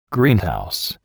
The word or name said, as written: greenhouse